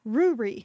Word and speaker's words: Ruri: I had no idea how to pronounce this. So I did a quick look up and it's, and they were like, Ruri. It's like, and so someone actually wrote like perfect. Rory. They're like, no, Ruri.